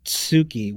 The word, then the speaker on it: Tsuki: Because like literally, I like you is Suki, which is S-U-K-I. Mm-hmm. And the moon is Tsuki with T-S-U-K-I. – Gotcha. See? So that means that he could hear her, but it was a very small difference in the pronunciation.